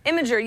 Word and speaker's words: Imgur: You might have heard of it as Imgur. That's how I used to pronounce it. It's pronounced Imgur? – Imgur, yeah, because, that makes more sense, right? – Yeah.